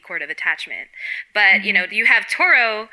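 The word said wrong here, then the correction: Tohru: I keep saying her name wrong. Toru.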